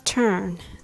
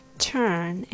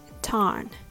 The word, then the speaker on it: turn: When you say the word turn, don't open your mouth too wide. Say turn and not tarn.